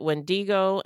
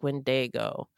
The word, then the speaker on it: Wendigo: I noticed that you mispronounced Wendigo." Yeah, we mispronounced it Wendigo, and it actually sounds like Wendigo.